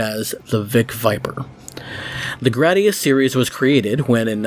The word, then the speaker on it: Gradius: Gradius, which, yes, officially seems to be pronounced like grad instead of grade, or grod for those people,